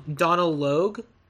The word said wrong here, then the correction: Donal Logue: Oh, yeah. I believe it's Donal. Donal Logue? – Donal Logue.